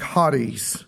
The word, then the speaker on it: charis: The C there is not really pronounced. Oftentimes, we say charis. You're either from Kentucky or have some weird accent like me. But it's actually pronounced in Greek, charis is the idea.